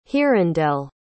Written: hirondelle

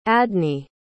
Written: adney

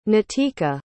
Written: nitika

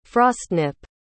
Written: frostnip

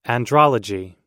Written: andrology

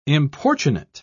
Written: importunate

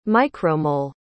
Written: micromole

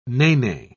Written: nene

